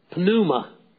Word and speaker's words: pneuma: And so it says here to give breath, pneuma. We talk about pneumatology. It's the word here, pneuma. Pneuma, as we say it in English, we have a silent P, but the Greek doesn't. To give spirit to the image.